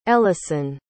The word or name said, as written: Ellison